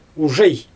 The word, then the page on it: uʒej: Xyzyl